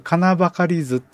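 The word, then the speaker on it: 矩計図: 使用書仕上げ表に書いてなければ、金ばかり図っていう、これ金ばかり図って読むんですけれども、金ばかり図を見てください。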